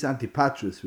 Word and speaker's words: Antipatris: Antipris is Antipatris, really, it's pronounced, is in Yehuda, but it's near Gol.